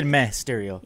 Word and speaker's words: Mysterio: You guys are saying Mysterio. I'm saying Mysterio. – I'm saying Mysterio. – No, you said it wrong. – Yeah. – What did Mysterio. – He say? He said it wrong twice. – Yeah, you said it wrong twice, but it's Mysterio.